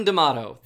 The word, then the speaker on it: D'Amato: Domato. Am I saying that right? – It's D'Amato, but we'll let it fly for right now. – Dr. D.M. D'Amato.